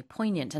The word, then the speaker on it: poignant: You don't pronounce the G. It's not poignant.